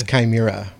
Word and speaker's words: Chimera: Your book, The Shimmer of Prague, did I pronounce that right? – It's Chimera. – See, I can't even get that right. – It's from the Greek.